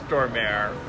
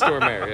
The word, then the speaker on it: Stormare: I'm sorry, but it's Stormare. – I think it's Stormare, yeah.